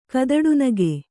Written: ಕದಡುನಗೆ

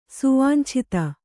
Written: ಸುವಾಂಛಿತ

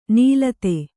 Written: ನುಲಿತ

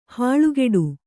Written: ಹಲ್ಕಡಿ

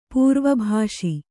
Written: ಪ್ರವಿಭಾಸಿ

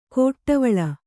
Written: ಕೊಟ್ಟವಳ

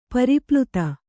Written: ಪರಿಪ್ಲುತ

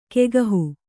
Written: ಕೈಗಾಹು

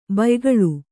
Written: ಭಯಾಕುಳೆ

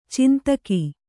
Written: ಛಂದಕ

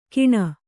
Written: ಕೀಣ

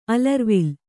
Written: ಅಲರುವಿಲ್

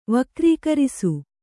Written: ವಕ್ರೀಕರಿಸು

